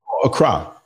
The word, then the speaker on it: Accra: I've seen your how do you pronounce it? A crew? Where are you again? What is it called? – Accra. Accra.